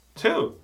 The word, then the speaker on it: two: Observe, não é chew, chew é mastigar. 2, é bem suave. Ta, ta, ti, to, 2. É a mesma pronúncia da preposição to, ou do também em inglês, T-O-O. Two.